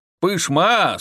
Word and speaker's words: Пышма: Пышма с ударением на второй слог, если кто не знает, это само».